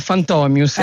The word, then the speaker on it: Fantomius: Io lo pronuncio Fantomius, ma invece Fantomius qua. – Perché... Sì, allora la pronuncia è Fantomius, ecco.